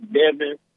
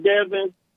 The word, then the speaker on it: Devon: Hey, man, and hey, look, I know, you know, if you look at the original name and how my name is spelled, it is like Devonshire. So technically it is Devon.